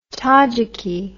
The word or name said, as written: tadzhiki